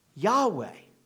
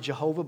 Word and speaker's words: Yahweh: Sometimes people will pronounce it Jehovah, but that does not seem to be in any way the way you'd pronounce the Hebrew text of scripture.